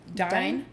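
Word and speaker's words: Dyne: Dine? – Yeah.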